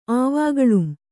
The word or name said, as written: ಆವಾಗಳುಂ